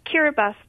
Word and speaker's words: Kiribati: It's Kiribati, but there's a trick at the end. It's K-I-R-I-B-A-T-I. The T-I is pronounced as an S sound in Kiribati.